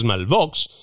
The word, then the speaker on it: Malveaux: Susan Malvox, se escribe Malveaux, pero es Malvox,